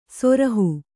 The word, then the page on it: ಸುರುಹು: suruhu